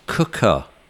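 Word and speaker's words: Quooker: Anyway, this podcast is sponsored by Quokka, pronounced Cooker.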